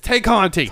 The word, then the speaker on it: Tay Conti: How do you say Ty Conti? – Ty Conti. – Every time I was telling Daryl this, I was like, it says Tay. It says Tay. It's Tay Conti.